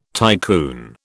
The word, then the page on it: tycoon: British English